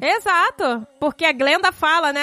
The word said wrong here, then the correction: Glinda: Não, a Glinda não. A Glinda. Você tá pronunciando errado.